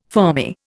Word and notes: fermi: Indian

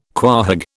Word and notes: quahog: British English

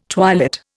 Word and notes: twilit: Indian

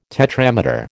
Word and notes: tetrameter: American English

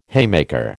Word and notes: haymaker: American English

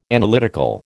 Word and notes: Analytical: American English

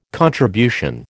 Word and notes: contribution: American English

